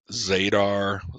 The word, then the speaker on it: Zadar: We live in a town called Zadar. Z-A-D-A-R. You would pronounce it Zadar, Zadar, whatever you dumb fucks say.